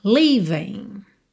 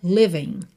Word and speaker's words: leaving: This night the dream was leaving, leaving. Atenção para você sempre caprichar nessa sílaba leaving, leaving, tá? Para você não dizer living, living, porque aí seria vivendo, morando, né? L-I-V-I-N-G.